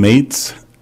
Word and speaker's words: Mates: My name is Ben Mates, and that's spelled M-A-T-E-S, and I live in Sugar House.